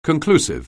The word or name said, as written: conclusive